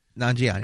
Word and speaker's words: Nanjiani: Kumail Nan, I'm going to mispronounce Nanjiani. his last name. Nanjiani.